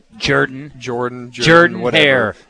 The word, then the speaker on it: Jordan-Hare: We had no Jordan-Hare.